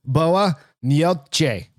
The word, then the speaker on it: boa noite: I listen to 30 different pronunciations of it and it's all boa noite.